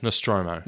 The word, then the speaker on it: Nostromo: I think it's pronounced Nostromo. – Nostromo. We'll go with that then, won't we? – Yep, 7 crew.